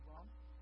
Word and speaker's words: Abram: The Bible says when Abram was 99 years old, now you can say Abram, and that's okay, that's actually the correct way to say Hebrew Abram.